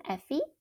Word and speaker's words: Effie: So it's my first name, Effie, E-F-F-I-E, and then my last name, Cao, so it's spelled C-A-O.